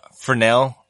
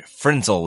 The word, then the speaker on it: Fresnel: You can carry a little, Fresnel lenses. I'm saying it right now, guys. Instead of my preferred Frenzel lens.